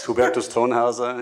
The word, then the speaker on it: Hubertus Thonhauser: Did I butcher your name? – Yeah, it's not bad actually for the first try. It's Hubertus Thonhauser. It's an Austrian dialect, but it's okay, Ahmed.